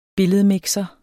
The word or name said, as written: billedmixer